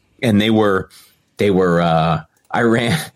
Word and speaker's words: Iran: Saying, you say this, it's Iran.